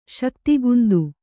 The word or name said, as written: ಶಕ್ತಿಗುಂದು